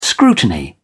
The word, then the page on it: scrutiny: British English